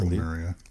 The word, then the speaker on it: Lemuria: I'm not sure how you say it. – I think it's Lemuria.